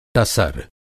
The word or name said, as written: टसर